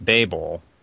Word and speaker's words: Babel: No, but I liked Babel. Am I saying that right? – Yeah, yeah.